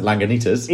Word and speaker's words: Lagunitas: Is that how I pronounce it? – Yeah, well, I pronounce it Lagunitas. – Either way. – So I think the same. – Absolutely nailed it. – Well, I think we pronounce it the same.